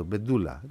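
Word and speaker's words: Pedullà: Πάντως επειδή το είδα πια και σε site, δηλαδή και στο σε site, σε μεγάλα site εδώ, ο Αλφρέντο Μπεντούλα, εκεί τονίζεται, Μπεντούλα.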